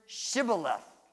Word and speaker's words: Shibboleth: Whenever a person was crossing the river, Jephthah's men would say this, say the word Shibboleth. Now, Shibboleth is a word that means stream of water or flood. The people in Gilead, Jephthah's area, pronounced it Shibboleth. The people from Ephraim called it Shibboleth.